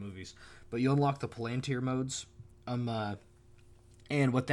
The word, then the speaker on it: Palantir: What we wound up doing is, once you beat the game, you unlock the they're called Palantir modes, and, well, Palantir is the movie pronunciation. It's actually probably Palantir, but Palantir.